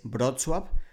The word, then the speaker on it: Wrocław: Wrocław es un nombre difícil de pronunciar para los extranjeros, especialmente para hispanohablantes porque en su nombre tienen una L como con un palito que se pronuncia en polaco como UO. La pronunciación correcta es Wrocław, pero algunas personas eh, de España la conocen como Breslavia, que digamos es la traducción literal.